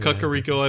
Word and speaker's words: Kakariko: I think I think because of the way we did, I forget how we decided we pronounced this village. Kakariko. Kakariko. – Kakariko. – Kakariko, I believe, is what we actually settled on.